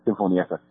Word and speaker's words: Sinfonietta: Yeah, Sinfonietta.